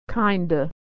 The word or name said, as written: kinda